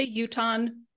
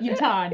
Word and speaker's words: Utahn: Lots of ways. Most of us say Utahn, but Utahn.